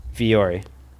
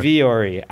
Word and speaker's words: Vuori: Viore. I pronounce things strangely, but this is correct. Viore. Like Viore. – V-U-O-R-I. – Yes, but they do have a pronunciation guide, and it's just what I say.